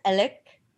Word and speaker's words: Alex: And his name, even though it's spelled Alex, it's pronounced Alec. – Oh, Alec, really?